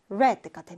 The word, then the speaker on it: read: 우리 read가 이제 과거형으로 쓰이면 그때는 발음이 red가 됩니다. 빨간색 할 때 그 red랑 발음이 같아져요.